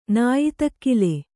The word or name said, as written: ನಾಯಿತಕ್ಕಿಲೆ